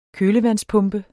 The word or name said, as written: kølevandspumpe